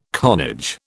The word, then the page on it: carnage: British English